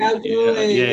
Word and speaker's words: Kalgoorlie: It was like Kalgoorlie, something. – Kalgoorlie, yeah.